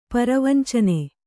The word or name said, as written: ಪರವಂಚನೆ